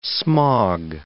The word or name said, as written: smog